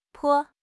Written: 坡

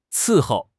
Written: 伺候

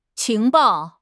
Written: 情报

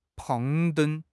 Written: 攀登

